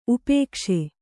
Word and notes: ಉಪೇಕ್ಷೆ: upēkṣe

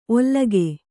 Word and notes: ಒಲ್ಲಗೆ: ollage

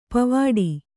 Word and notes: ಪೋವಿಡು: Kannada

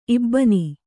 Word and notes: ಇಬ್ಬನಿ: ibbani

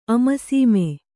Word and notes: ಅಮರಸೀಮೆ: Kannada

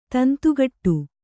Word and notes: ತಂತಿಕೊಡು: tanti koḍu

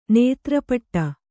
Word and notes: ನೆತ್ತರ್ಪಟ್ಟೆ: Kannada

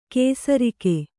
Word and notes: ಕೇಸರಿಕೆ: kēsarike

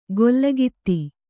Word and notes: ಗೊಲ್ಲಗಿತ್ತಿ: gollagitti

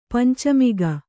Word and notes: ಪಂಚಮುಖಿ: panca mukhi

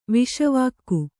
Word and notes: ವಿಷವಾಕ್ಕು: Kannada

